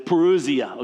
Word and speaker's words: parousia: It's parousia, okay? When you look at how to pronounce it, that's not how they tell you to pronounce it. But parousia, it sounds good. And it's P-A-R-O-U-S-I-A, parousia.